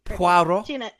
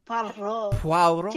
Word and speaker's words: Poirot: Perot, of course. Wait, I can't pronounce it. – Poirot. – Poirot.